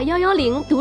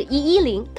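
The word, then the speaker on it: 110: 所以根据以上种种原因，在我们的生活中就产生了一个很有趣的现象：全国各地都把幺幺零读成幺幺零，而不是一一零。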